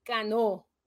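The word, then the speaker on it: Canó: Cano, porque lleva acento en la O.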